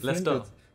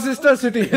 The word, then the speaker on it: Leicester: No, it's spelled differently. – Leicester. – Leicester City. – Leicester Sister City. – Leicester City. – That's how it's spelled. – Yeah. – Bro, like when they won the league, I always used to say Leicester Sister or whatever.